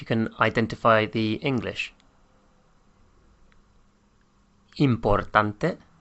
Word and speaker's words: importante: except there's an E at the end, which is pronounced. So important becomes importante.